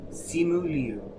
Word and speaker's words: Simu Liu: Simu Liu? – I said it right. I said it right for me. – Liu? Simu Liu.